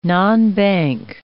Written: nonbank

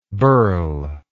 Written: birle

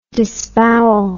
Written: disbowel

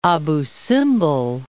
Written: Abu Simbel

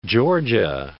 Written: Georgia

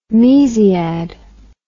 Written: mesiad

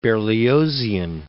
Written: Berliozian

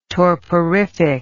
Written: torporific

